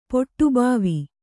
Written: ಪೊಟ್ಟುಬಾವಿ

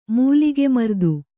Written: ಮೂಲಿಗೆಮರ್ದು

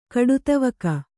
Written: ಕಡುತವಕ

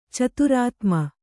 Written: ಚತುರಾತ್ಮ

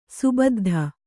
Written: ಸುಬುದ್ಧಿ

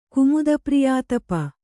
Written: ಕುಮುದಪ್ರಿಯಾತಪ

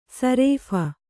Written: ಸರಾಫಿ

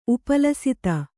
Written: ಉಪಲಸಿತ

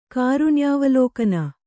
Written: ಕಾರುಣ್ಯಾವಲೋಕನ

